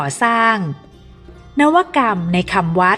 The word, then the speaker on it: นวกรรม: วัสดีค่ะวันนี้พบกับรายการคำวัดขอเสนอคำว่านวกรรม